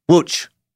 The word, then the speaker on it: Łódź: This word, by the way, oh boy, Łódź is spelled L-O-D-Z. Yeah, you figure that out. L-O-D-Z, pronounced Łódź. Okay.